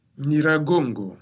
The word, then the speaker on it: Nyiragongo: Alors, oui, racontez-nous un peu, parce que pendant le mois de mai, il y a eu l'éruption du volcan Nyiragongo, si je le dis bien. – Nyiragongo. – Nyiragongo.